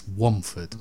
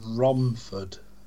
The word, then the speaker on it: Romford: Oh, it's the only town you can't say the R properly. No one can say it properly. It's Womford. – Romford. – Yeah, see, you just did it. Womford. – No, Romford.